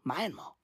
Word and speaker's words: Myanmar: I say, you know, Myanmar. Right? That's what I call it. That's how I pronounce the fucking word Myanmar. Because it is Myanmar. Right? Right? The word my is in the fucking name. The word my. It starts with the word my.